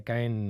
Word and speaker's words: Caen: Es, es investigadora postdoctoral en la Universidad de Caen,